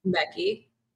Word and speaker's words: Mekki: Mecki.